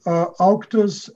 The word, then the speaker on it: Auctus: Did I say that right? – That is correct. Auctus, A-U-C-T-U-S, is the name of the publisher.